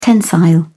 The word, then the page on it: tensile: British English